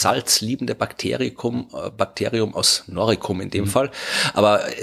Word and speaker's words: Noricum: Ja, das ist umstritten, aber ich glaube wahrscheinlich in dem Fall Noricum wird man es mit K aussprechen. Genau, also das ist das Halobacterium noricense.